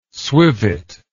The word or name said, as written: swivet